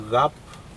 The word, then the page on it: caP: Aneityum